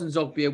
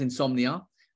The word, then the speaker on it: Nzogbia: Charles Nzogbia was known as Charles Insomnia.